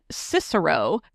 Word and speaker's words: Cicero: Now, Western Electric, for those who don't know, ran the Hawthorne Works Factory out in Cicero. Yep, I said it right.